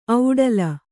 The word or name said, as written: ಒಡಲು